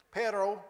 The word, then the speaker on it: perro: Perro. You're supposed to be able to roll your R's. I can't do it real well. P-E-R-R-O.